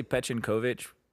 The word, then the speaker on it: Pechenkovic: Well, it depends on like how it's just what syllable you're emphasizing, right? So like it is. I just that's how I say it, but I've heard people in my own family that say Pechenkovic.